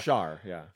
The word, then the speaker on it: Char: Char, yeah. – Char. – It's C-H-A-R, but it's pronounced Char. – Right, because Charlotte.